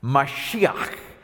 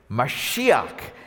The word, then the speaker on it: Mashiach: Mashiach. Mashiach is the word Messiah in Hebrew.